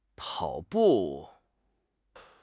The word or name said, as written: pao3 bu4